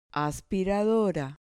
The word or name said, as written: aspiradora